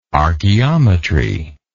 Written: archaeometrist

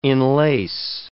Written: enlace

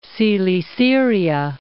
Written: Coele-Syria

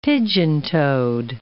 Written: pigeon-toed

